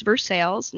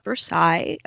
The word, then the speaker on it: Versailles: Vanderpump Rules stars Jax Taylor and Brittany Cartwright obviously are getting married at Versailles Castle in Kentucky, which, you guys, it's pronounced Versailles, not Versailles. Shocker.